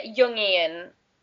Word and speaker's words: Jungian: And he also talked about, I'm totally going to mess up this word, I think it's pronounced Jungian or something like that. It's very hard to hear him pronounce it. – Yeah, Jungian. Like, yeah, yeah. – Like the philosopher, like Carl Jung.